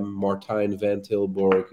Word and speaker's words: Martijn van Tilborgh: Martijn van Tilborgh is Facebook.